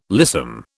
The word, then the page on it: lissom: British English